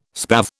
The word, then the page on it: spathe: British English